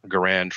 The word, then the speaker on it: Garand: I'm actually going to sell my M1 Garand to fund that purchase because I don't ever shoot my M1 Garand. Garand for all of you that want to say it the wrong way.